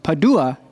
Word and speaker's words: Padua: He goes by the name of Tommaso Cappellato, hailing from Padua. Did I pronounce that right? – Yes. – Italy.